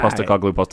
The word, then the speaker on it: Postecoglou: Postecoglou.